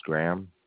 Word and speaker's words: Graham: Last name is Graham, Golf, Romeo, Alpha, Hotel, Alpha, Mike.